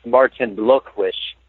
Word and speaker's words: Martin Blokwisch: So there's this guy, his name is pronounced Martin Blokwisch, because he's like a German guy.